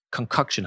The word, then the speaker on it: concoction: and then together they create a magical a concussion concoction. How do you say that? Concoction. Concoction. That um, concussion sounded pretty good too.